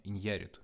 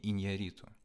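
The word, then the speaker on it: Iñárritu: И, как вы поняли, я неправильно ставил ударение, то есть э, правильное ударение Иньяриту, не Иньяриту.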